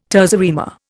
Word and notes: terza rima: Indian

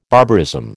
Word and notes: barbarism: American English

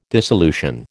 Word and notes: dissolution: American English

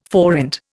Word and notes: forint: Indian

